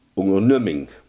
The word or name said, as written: onderneming